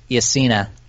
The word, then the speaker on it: Yesina: Let me fix your pronunciation. It's pronounced Yesina.